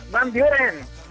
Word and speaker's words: Van Buren: No, pues tú la decías así como te la escribieron, pero se pronuncia Van Buren.